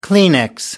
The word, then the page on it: kleenex: American English